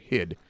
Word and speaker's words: Hyde: Mr. Heidi? – No, Mr. Hyde.